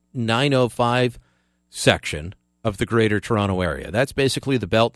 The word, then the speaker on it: Toronto: Man, I hate it when I pronounce that second T. Fortress Toronto. Just comes out sometimes. I say Toronto. As if I'm from somewhere else in Canada. You people in Toronto. Nobody near it pronounces it that way. It's Toronto.